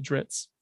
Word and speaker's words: Drizzt: but like lore wise to pronounce it Drizzt.